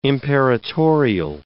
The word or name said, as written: imperatorial